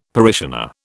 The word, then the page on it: parishioner: British English